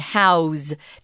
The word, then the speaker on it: house: we use the Z sound, house, to house somebody. You can't say, i want to house you. That's wrong. Mm. so remember, if house is a verb, you pronounce it with a Z, house, to house something or to house someone.